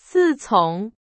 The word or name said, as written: 自从